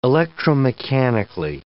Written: electromechanically

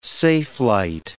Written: safelight

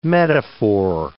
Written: metaphor

